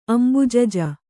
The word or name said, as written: ಅಂಬುಜಜ